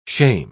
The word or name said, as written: shame